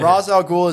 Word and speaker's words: Ra's al Ghul: Ra's al Ghul is absolutely right, so again...